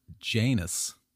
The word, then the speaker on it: Janus: This new date honored Janus, like anus with a J, a two-faced god.